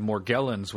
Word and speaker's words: Morgellons: I think maybe the Mayo Clinic So the basic description of Morgellons with a hard G is that your skin grows fibrous strings.